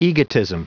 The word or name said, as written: egotism